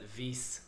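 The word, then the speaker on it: Weisse: I believe you pronounce that Weiss. – Come on. Weiss? – I think it's like Berliner Weiss.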